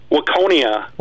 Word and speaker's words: Waconia: Waconia. – Waconia.